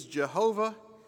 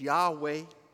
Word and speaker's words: Yahweh: The four-letter word, H-W-H. For a period of time, we thought that the pronunciation was Jehovah, but more recently, scholars led us to pronounce the name as Yahweh.